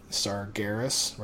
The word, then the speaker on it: Sargeras: But if you do have Blood of Sargeras or Sargeras, whichever way you want to pronounce it, there's a vendor now, Garrus, right? Yeah, I think it's a hard God. G, right? – Yes.